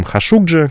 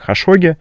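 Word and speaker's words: Хашукджи: Но после инцидента с Джамалем Хашугджи, которого чаще упоминают как Хашоги, можно поспорить о том, как правильно его вроде как тюркоязычную фамилию, написанную арабицей, правильно произносить. Ну, наверное, привычнее для слушателей будет Хашоги. – Ну, Хашоги — это как бы арабский вариант, да? – Да.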